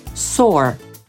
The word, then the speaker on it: soar: Soar wymówi Amerykanin jako sore, czyli tak jak polski sor.